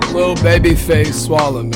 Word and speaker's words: Lilbabyfaceswalla: Lilbabyfaceswalla, man. – Okay, so it's Swalla. – Yeah. – Okay. – Skooms. – I wasn't sure where the S went.